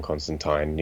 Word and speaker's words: Constantine: Like, it's for anyone that knows John Constantine, or John Constantine if you want to pronounce it correctly.